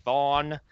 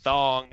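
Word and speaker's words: Thawne: As in Eobard Thawn, not Thong, as it's been previously mispronounced here.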